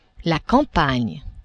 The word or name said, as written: campagne